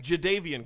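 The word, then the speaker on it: Jadeveon: So LeBron James had said, oh, number one overall went to, I can't pronounce this. Javon Clowney. I just was saying Jadavian Clowney.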